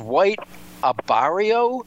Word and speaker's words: White Abarrio: and that is White Abarrio. Is that how you pronounce that? Saffy Joseph's horse? – Yep.